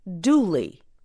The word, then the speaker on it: Dula: An example is the story of a young man whose last name was spelled D U L A but pronounced Dooley.